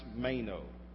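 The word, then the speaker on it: meno (μένω): That word remains is a Greek word that we pronounce meno.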